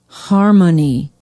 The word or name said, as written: harmony